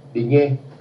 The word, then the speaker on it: Digne: Este joven Digne, creo que se dice así. – Digne. Sí. El francés.